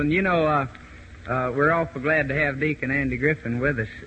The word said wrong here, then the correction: Griffith: Griffith. He said pronounce it with a T-H, so it's Deacon Andy Griffith.